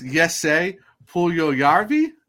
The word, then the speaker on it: Jesse Puljujärvi: All right, how do you say his name? – Yeah, I know it's Yesse Puljujarvi, if I was even close to that. – Puljujarvi, something like that. – Yeah, it's Yesse. So the Hurricanes did trade Patrick. – We have Andrew's nod of approval. Yes, that is how you say it.